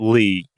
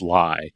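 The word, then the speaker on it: Li: It's Bruce Lee, L-I. I've also seen this pronounced Bruce Lai, which is kind of fun because it's not really Bruce Lee.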